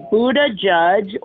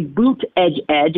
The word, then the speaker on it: Buttigieg: You can say Buttigieg or you can say Buttigieg if you say it fast. That comes straight from the man's husband. That's what he said on Twitter.